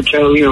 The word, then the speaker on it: Catalina: It's Catalina, right? Oh, am I pronouncing that wrong? I hope not. – No. – Yeah. – No, you're pronouncing it right. – That sounds familiar. I do remember Catalina.